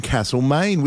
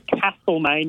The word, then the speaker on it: Castlemaine: This is at Camp Reserve, love the name, in Castlemaine, and make sure you pronounce it Castlemaine, not Castlemaine.